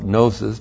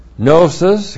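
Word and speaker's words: gnosis (γνῶσις): The noun, gnosis, and I know you call it gnosis, but no well-respecting Greek would ever say gnosis. G-N long O-S-I-S is gnosis, comes from gnosko.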